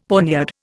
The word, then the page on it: poniard: Indian